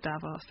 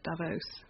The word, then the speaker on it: Davos: Everybody else calls it Davos, except for the Americans that call it Davos. Inexplicably changed vowel sounds.